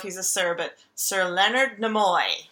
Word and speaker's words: Leonard Nimoy: I've read both of Leonard Nimoy.